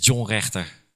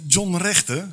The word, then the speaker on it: John Rechter: Vertel eens, wie is John Rechter? – John Rechter, heel goed uitgesproken trouwens, want de meesten zeggen Johnny.